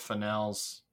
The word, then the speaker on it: Fennell: Barry Keoghan's going to be in Saltburn, which is Emerald Fennell's? – Fennell, I believe. The first Fennell. Way you pronounced it, yes.